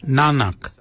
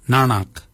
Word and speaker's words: nangag: So for neck we say nanak, nanak. Nanak is spelled N-A-N-G-A-G.